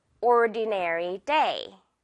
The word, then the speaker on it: ordinary: It was an ordinary 보다는 네, nary, ordinary day. Okay, 여러분, A-R-Y.